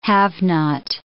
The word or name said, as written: have-not